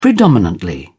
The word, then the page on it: predominantly: British English